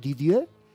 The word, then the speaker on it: Didier: Me ha dicho José María Torrijos, no pronuncies la R del final. Didier, el organista francés.